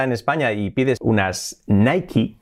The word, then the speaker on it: Nike: Eso sería prácticamente correcto, pero ocurre que en Estados Unidos no dicen Nike, sino dicen Nike. No entiendo por qué, pero se pronuncia así, Nike. Le añaden una I al final, Nike.